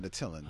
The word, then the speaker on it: Attilan: Adelan or Attilan? – See, that's, I think I pronounce it Adelan always, but. – I always thought it was Attilan. – Really? – Yeah, I mean. – Yeah, I think I always said Attilan. – Oh, I said Adelan. – There's no accent mark to tell me that I need to, like, really jump up with that second I. – Yeah. – Well, it's Attilan.